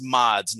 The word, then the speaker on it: Mads: And I did actually find out that that's how you pronounce his last name, although it's Mods, not Mads.